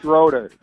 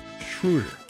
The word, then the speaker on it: Schroeder: Hey, that's Dennis Schroeder. – All right. You didn't even say his name right. No. All right. That is incorrect. Thank you. Hang up on yourself. Schroeder.